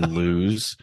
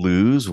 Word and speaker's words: Lews: Are you saying lose? Wow, I thought it was loose. Like the goose is loose. – L-E-W-S, right? – Yeah.